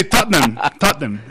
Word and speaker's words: Tottenham: ¿Son dos sílabas? – Sí, Tottenham.